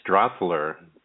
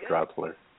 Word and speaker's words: Drothler: Jeff, could you say your last name for me? – It's Drossler, D-R-O-T-H-L-E-R, Jeff Drossler.